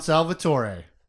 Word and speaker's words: Salvatore: Pick a vowel sound, Salvatore. Let's rhyme it to his initial.